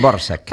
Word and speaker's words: Dvořák: Però de moment acabarem el curs amb Rusalka, d'Antonín Dvořák, segurament no ho he pronunciat bé, ni de càrrec de l'Albert Ferrer Flamarí. – Borsak, ens, sí? Ens apunta l'Albert.